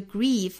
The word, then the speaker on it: grief: If I don't learn to, 若读 to face my grief, 那这个 grief 我们注意是长元音 e 的发法。那大家看到这个 ie 的字母组合可能会迷误一下，会发成一个 a 的发音，比如说 grave 之类的。其